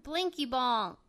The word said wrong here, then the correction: Binky Bong: Oh wait, Binky Bongs. – Silent L. Binky Bong.